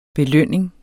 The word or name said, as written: belønning